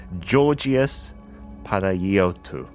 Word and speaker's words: Panayiotou: Or it could be Panayiotu. I'm not entirely familiar. How do you spell it? P A N A Y I O T O U, but you're focusing on the wrong part of the name.